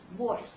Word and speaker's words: voice: 那我们来看一下下面一个单词，voice。好，在发这个音的时候，我们请大家注意它的辅音的发音，就是这个 v 啊。很多人发的时候发成我啊，不要我。那么注意一下，这是一个相当于是一个，它是一个摩擦音。那么发音的时候，这个 v 注意上齿碰下唇啊，很自然的震动 v。